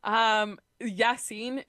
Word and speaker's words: Yasiin: Anyways, Yassine, is that how you say it? I don't Correct. – Know. – Correct.